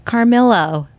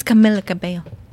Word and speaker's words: Camila Cabello: And she keeps on making fun of my Blakey because he doesn't pronounce her name right. – Oh, so funny. – So he's like, Carmelo. – I love Blake. – And she's like, it's Camila Cabello.